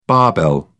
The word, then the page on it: barbell: British English